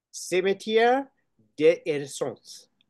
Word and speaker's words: Cimetière des Innocents: Cimetière des Innocents.